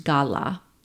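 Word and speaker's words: gala (גלה): And the Hebrew word we translate as reveal in the Bible, pronounced gala, which means to uncover and report, which is what the woman did when they learned that Jesus was resurrected.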